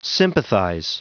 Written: sympathize

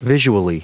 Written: visually

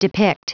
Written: depict

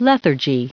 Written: lethargy

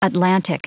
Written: atlantic

